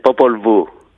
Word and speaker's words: Popol Vuh: Well, let's look at a bit of your work, an example, under the title Popol Vuh. Am I saying that correctly? – Yes, Popol Vuh.